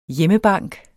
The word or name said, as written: hjemmebank